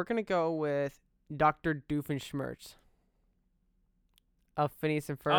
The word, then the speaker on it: Doofenshmirtz: Doofenshmirtz.